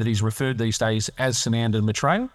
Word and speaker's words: Sananda Maitreya: Sananda Maitreya.